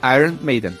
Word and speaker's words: Iron Maiden: Então tá certo a pronúncia, Iron Maiden.